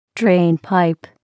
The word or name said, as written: drainpipe